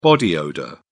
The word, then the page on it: body odour: British English